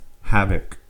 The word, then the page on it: havoc: American English